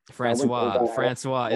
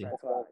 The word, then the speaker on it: Francois: Francois. Is that how you say it? Or are you trying to make it sound fancy? – Francois. Yeah, yeah.